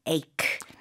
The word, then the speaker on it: ache: A-C-H-E 있지만 발음은 ache. – H 아닙니다.